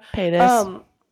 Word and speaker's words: Paytas: Trisha Paytas, is that how you say her name? Whatever, I don't care if I butcher it. I'm sorry, Trisha. – Paytas. – Close enough.